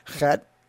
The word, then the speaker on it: Chet: It has that little guttural on the front of it, and so you kind of pronounce it like that, Chet.